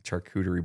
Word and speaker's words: charcuterie: And she's into charcuterie. Am I saying that right? – Charcuterie. Something like that.